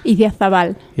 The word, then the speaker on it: Idiazabal: How do you say the one that starts with an I-D? – Idiazabal.